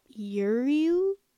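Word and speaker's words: Ériu: In Old Irish, she was known as Uriu. Uriu? I can't pronounce that. – Sure. – E-R-I-U. In modern Gaelic, it's Ir.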